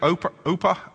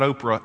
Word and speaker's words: Orpah: And these two Moabite women, what they do, their names are Orpah. I want to say Oprah, but it's not Oprah. It's Orpah. Excuse me.